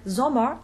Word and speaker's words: Sommer: In German, on the other hand, we don't phonetically double the consonant in transcribing it. A word like Sommer, S O M M E R, which means summer, would be Z, open O, M, upside down A.